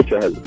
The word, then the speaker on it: Chahal: And I know a lot of people who have different versions of pronouncing it. Chahal, Chihal, Chahal. – Yeah. – No one seems to get it right. What is his full name? – His full name is Yuzvendra Chahal.